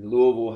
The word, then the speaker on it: Louisville: Or Louisville.